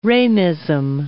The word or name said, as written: Ramism